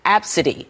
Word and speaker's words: Abcde: Well, her daughter's name is spelled A-B-C-D-E and pronounced Absidy.